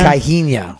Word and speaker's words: Caixinha: Let me try to say his name right. He's Portuguese. Pedro Caixinha. – Pedro Caixinha? – Caixinha.